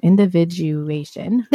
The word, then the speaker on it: individuation: What kind of couple is individualization? I'm going to butcher that this whole time. Individuation. – There you go.